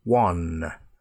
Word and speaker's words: one: Now, there are also some words that we pronounce with a W sound, but they're not spelt with a W, but they have a W sound. For example, one one ice cream cone, please.